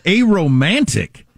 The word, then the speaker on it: aromantic: I'm sorry, I mispronounced that. Yes. – Is that a group now? Are they demanding the pleasant smelling are now marching and demanding their rights? – Aromantic. So non-romantic, not a romantic.